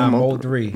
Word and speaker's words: Mo3: Is it MO3? – No, Mo3.